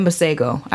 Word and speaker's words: Masego: And then Masego.